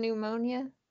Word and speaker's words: pneumonia: Or the N, or the P in pneumonia.